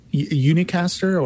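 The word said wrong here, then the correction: Ennuicastr: or Onwecaster. – Onwecaster. I got to separate those.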